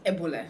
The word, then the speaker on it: Ebule: Am I saying that right? – Ebule.